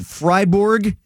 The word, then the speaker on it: Freiburg: Josh, am I saying it right? Is it Freiburg? Is that how you say it? – It is. And I don't know if you've ever been to Switzerland, Tim.